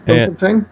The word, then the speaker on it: Bloemfontein: They're describing a man here who lives in a place I can't pronounce, Bloemfontein, maybe? – Yes, Bloemfontein.